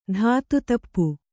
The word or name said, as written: ಧಾತುತಪ್ಪು